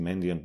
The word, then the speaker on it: Mandiant: Mandiant, alebo ak sa máme po anglicky Mandiant, myslím, že to vyslovujú, tak kedysi bola samostatná firma, security firma, teraz patrí pod firmu FireEye.